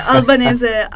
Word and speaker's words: Albanese: the Albanese, um, the Albanese relationship.